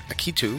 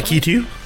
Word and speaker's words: Akitu: They marked the occasion with a massive religious festival called Akitu. Am I pronouncing that properly? – Akitu? – Akitu? – Akitu? – A-K-I-T-U.